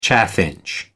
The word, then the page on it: chaffinch: American English